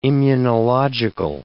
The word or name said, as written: immunological